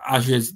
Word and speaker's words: Azure: Titanfall 1 was a good example of how to use the cloud, because that game was run on Azure's dedicated servers. Apparently, that's how you say it, Microsoft Azure dedicated servers.